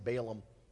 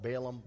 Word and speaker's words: Balaam: Some people pronounce the name Balaam. I've heard it both, Balaam, Balaam. The real sad ending to that story is not about the talking donkey.